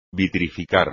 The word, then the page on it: vitrificar: espagnol